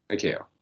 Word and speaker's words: ICAO: ICAO, is that how we're saying how it's pronounced? ICAO? – ICAO, yes.